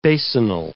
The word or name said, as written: basinal